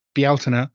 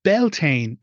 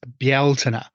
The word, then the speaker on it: Bealtaine: Now, those are the pronunciations of those words. It's not ever and never has been Samhain and never will be. It is Samhain. Also, it's never Beltane. It's Bealtaine. So those are the words.